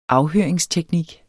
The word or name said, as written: afhøringsteknik